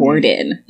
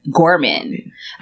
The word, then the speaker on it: Gorman: Amanda I keep saying Gorman. Gordon. Gorman.